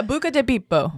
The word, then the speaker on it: Buca di Beppo: That is not how you say it. It's Italian. – Yeah, Buca di Beppo.